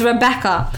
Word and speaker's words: Rabecaa: It's Rebecca.